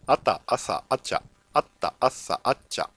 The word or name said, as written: consonant